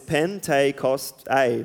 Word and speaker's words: Pentecost: The name Pentecost comes from the Greek word, which pronunciated like this, Pentecost-a, which is pretty close to what we say now.